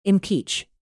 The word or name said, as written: impeach